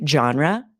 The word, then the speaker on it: genre: That is the French pronunciation, genre. It means a type or class, but it's often pronounced with the harder G, genre. It's sort of just anglicized that way. And sometimes people add an extra E to make this three syllables, like genre or genre.